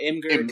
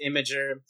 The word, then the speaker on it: Imgur: I call it Imgur because it looks like it says Imgur, but it's actually pronounced Imager.